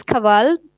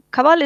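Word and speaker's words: Kanwal: my name is Kaval, and if you see the spelling of my name, I have K A N. So N is silent, but yeah, Kaval is perfect and you are pronouncing it perfectly fine.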